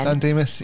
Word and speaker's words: San Dimas: San Dimas, yeah. – San that's Dimas. – Fine. Yeah. – Oh, it's like Merry Christmas, San Dimas.